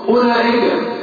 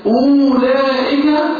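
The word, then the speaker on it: أولئك: الف على همزه وبعدين واو والواو دي عليها ايه؟ صفر مستدير. بقولها ازاي بقى؟ اولئك ولا اولى؟ من الهمزه للايه؟ لله. والواو الموجودة معروف عليها اسمها مستدير، معناها النطق. كذلك قولي قولي قولي بتلاقي ألف برضه، ألف عليها همزة ماشي، وبعدين واو والواو عليها إيه؟ صوت مستدير، أيوة لا تنطق. خش على طول من الهمزة لله قول قولي، واضح؟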